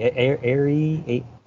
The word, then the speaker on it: Aerie: Aerie, yeah, I say I, I say Ellie because you're, you're the young one on there.